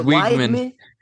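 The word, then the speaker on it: Weigman: It's Wegman. Wegman. – Wegman? – You butchered it.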